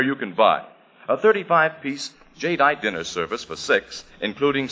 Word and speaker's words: Jadeite: Now, it's spelled J-A-D-E-I-T-E, Jadeite.